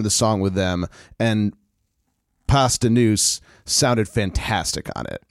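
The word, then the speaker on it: Posdnuos: And they, the surviving members, Paz de Nuce, I'm saying that right, right? – That's correct.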